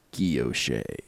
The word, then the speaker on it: guilloche: Google has a little speaker button where it will pronounce it, and that was how it was pronounced. So that's how I've always said it, is Guilloche.